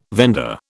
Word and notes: vendor: British English

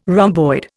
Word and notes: rhomboid: Indian

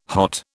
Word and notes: hot: British English

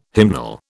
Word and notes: hymnal: British English

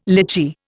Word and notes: lychee: Indian

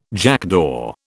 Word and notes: jackdaw: British English

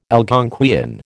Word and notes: Algonquian: American English

